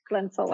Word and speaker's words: Klentsova: And I don't know if I butchered that name. – It's absolutely perfect. Klentsova.